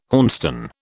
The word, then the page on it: hornstone: British English